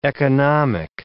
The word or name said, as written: economic